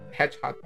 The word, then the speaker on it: Hedgehog: Hedgehog. ¿Lo dije mal? – No, está perfecto. – British English perfect, okay.